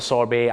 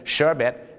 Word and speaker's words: sorbet: ชาวอังกฤษก็จะออกเสียงว่า sorbet เอามาจากฝรั่งเศสชาวอเมริกันก็จะเป็น sherbet ชาวอังกฤษก็จะเป็น sorbet นั่น